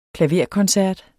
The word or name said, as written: klaverkoncert